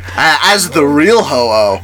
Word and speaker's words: Ho-Oh: So when describing certain works of art that is based off of English-speaking art historians often refer to them as ho-ho birds, a name derived from ho-oh, spelled H-O-O, which is the Japanese pronunciation of Fang Huang. So it's ho-oh. – So literally slap an H at the end of that.